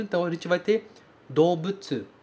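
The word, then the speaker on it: Dobutsu (動物): Dobutsu, a gente escreve dou, mas a gente pronuncia do.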